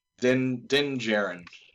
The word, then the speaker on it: Din Djarin: Din Djarin.